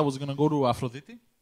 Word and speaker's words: Aphrodite: Aphrodite.